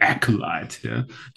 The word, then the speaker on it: Acolyte: Aber er sagt selber zu mir bei Akolyt, also wie spricht man es richtig aus? – Der Acolyte.